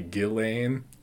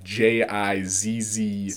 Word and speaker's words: Ghislaine: It's technically Ghislaine, like you don't pronounce the S. But if you want to pronounce it correctly, then it's Ghislaine, like Jizzlane.